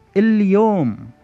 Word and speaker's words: اليوم: With some words, it is fully pronounced. That means we do hear it, as in the word اليوم, which means the day or also today.